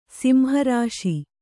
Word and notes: ಸಂಹರ್ಷ: samharṣa

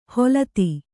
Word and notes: ಹೊಲತಿ: holati

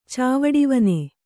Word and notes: ಚಾವಡಿವನೆ: kannada

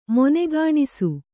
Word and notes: ಮಣ್ಣುಗಾಣಿಸು: maṇṇugāṇisu

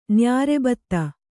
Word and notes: ನ್ಯಾರೆಬತ್ತ: nyāre batta

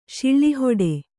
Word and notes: ಶಿಳ್ಳಿಹೊಡೆ: Kannada

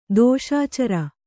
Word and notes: ದೋಷಾಚರ: dōṣācara

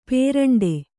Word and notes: ಪೇರಂಡೆ: pēraṇḍe

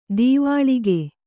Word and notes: ದೀವಾಳಿಗೆ: dīvāḷige

